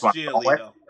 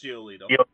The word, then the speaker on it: Giolito: You did. But that's Giolito. It's Giolito.